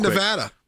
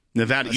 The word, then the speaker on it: Nevada: All right, Nevada. – Nevada, you said it right. – I always say it right.